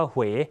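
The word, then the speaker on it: Huế: เหวคำว่าเมืองเวนะครับภาษาเวียดนามที่ถูกต้องต้องออกเสียงว่าเมืองเหวไม่ใช่เมืองเว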